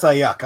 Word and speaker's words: Aiyuk: It's pronounced Aiyuk.